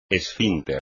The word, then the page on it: esfínter: espagnol